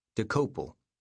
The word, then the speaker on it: Dokoupil: Main entry, DOKOUPIL. Pronunciation, DeCopel.